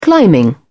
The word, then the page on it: climbing: British English